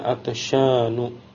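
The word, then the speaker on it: atasyanu (عطشانُ): Dengan dua sebab ini, maka kata atasyanu tidak bisa ditanwin atau tidak menerima tanwin. Jadi bacanya atasyanu. Kemudian wa masajidu, kata masajid ini jamak dari masjidun.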